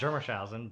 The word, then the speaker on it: Germershausen: Did I say that right? – Yeah, that's close enough. – Okay. – It's Germershausen, but... – Ooh, not even close.